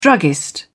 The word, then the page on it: druggist: British English